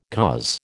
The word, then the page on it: coz: American English